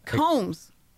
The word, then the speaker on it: Coombs: Samuel Combs, C-O-O-M-B-S, but they pronounce it Combs.